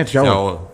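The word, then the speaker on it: Thiaw: Θιάο, δεν ξέρω πώς διαβάζεται. – Θιάο, ναι, Θιάο.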